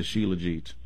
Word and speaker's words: shilajit: Yeah, and so I think it's spelled S-H-I-L-A-J-I-T, but I think you pronounce it shilajit.